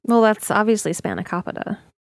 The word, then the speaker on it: Spanakopita: Spanakopita is the correct pronunciation there.